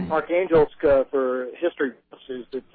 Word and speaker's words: Arkhangelsk: First of all, can you start off by telling us how to pronounce the name of the city and then give us some background information on these Arctic forums and your role in the event? – Sure. The meeting was called Arkhangelsk.